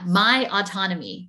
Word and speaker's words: Mytonomy: And it's spelled M Y T O N O M Y. – That's right. Yeah. My autonomy,